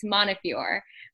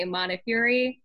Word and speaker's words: Montefiore: You almost said it correctly, Wally. It's Montefiore. But some people do say Montefiore, and I think both pronunciations are totally okay.